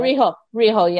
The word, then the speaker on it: Riho: If I said it's Rio from AEW. – Oh, Riho, yeah.